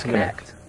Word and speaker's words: Kinect: There's been some other interesting kinetic. Kinect? Kinect? – I think it's kinetic. Like, Kinect? – Oh, it is Kinect? – Yeah, it's Kinect. – Oh, okay. I've been saying it wrong.